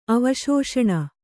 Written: ಅವಿಶೇಷಣ

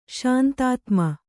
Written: ಶಾಂತಾತ್ಮ

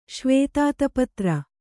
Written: ಶ್ವೇತಾತಪತ್ರ

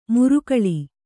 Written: ಮುರುಕಳಿ

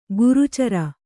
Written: ಕರ್ಚೂರ

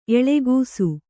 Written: ಎಳಗೂಸು

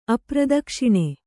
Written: ಅಪ್ರದಕ್ಷಿಣ

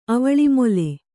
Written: ಅವಳಿಮೊಲೆ

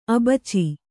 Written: ಅಬಚಿ

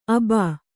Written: ಅಬಾ

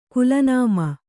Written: ಕುಲನಾಮ

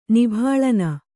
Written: ನಿಭಾಲನ

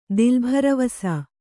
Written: ದಿಲ್ಭರವಸಾ

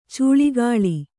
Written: ಚಿಲುಕೂಲಿ

